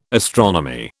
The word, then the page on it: astronomy: British English